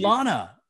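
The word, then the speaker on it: B'Elanna: Baylana, which is the way she pronounces it, Baylana, right? Instead of Bulana, and I've been doing Bulana for most of the series, and all of a sudden, I come out with Baylana.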